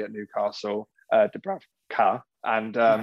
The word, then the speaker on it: Dubravka: Is it Dubravnik? – Dubrav, um, Dubravka. – Dubravka, that's it, yeah.